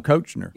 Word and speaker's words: Koechner: Greg landed on Koechner. – Yeah, he says it in the video, too.